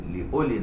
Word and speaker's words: أولي: لأولي الواو محذوفة نطقا مثبتة كتابة، بس كأنه مش واو، تبقى مكتوبة وكأنك مش شايف، تقول لأولي النهى.